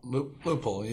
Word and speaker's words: Lupul: Lupul, yeah.